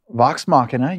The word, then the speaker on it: Machina: Because when I was watching Vox Machina, am I pronouncing that correctly? – Yes. – Machina? – Vox Machina, you're doing it perfectly.